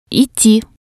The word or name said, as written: idti